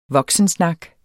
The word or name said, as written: voksensnak